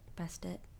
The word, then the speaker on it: Bastet: My favorite Egyptian god is Bastet. Bastet.